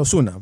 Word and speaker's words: Ozuna: Yo cada vez que lo veo, yo sé que se pronuncia Ozuna, pues con Z allá en Puerto Rico y todo ello.